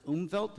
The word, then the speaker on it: Umwelt: It's spelled U-M-W-E-L-T, which I assume is pronounced Umwelt. Does that sound right for you German speakers out there? Umwelt, I'm getting some thumbs up.